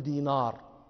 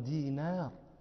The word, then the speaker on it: دينار: النون رقيقة، النون حرف رقيق، لا نقول دينار، هذا نطق عجمي، نحن عرب نقول عبد الله بن دينار.